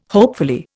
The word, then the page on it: hopefully: Indian